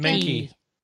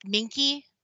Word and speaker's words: Minky: how do you pronounce his last name? – Minky. – Mike Minky. – Minky. – Yeah, it's like monkey but with an E instead of.